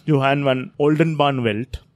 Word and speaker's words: Johan van Oldenbarnevelt: So in 1602, under the leadership of their prime minister, then prime minister, Johan van Oldenbarnevelt.